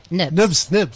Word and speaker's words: Knibbs: Nibs. – Yeah. I pronounce it. It's spelled K-N-I-B-B-S. – Silent K, yes.